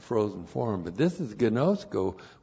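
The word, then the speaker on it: Gnosko (γινώσκω): And then we have our friend gnosko. G-I-N long O-S-K long O. Gnosko.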